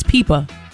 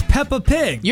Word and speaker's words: Peppa Pig: It's Peepa.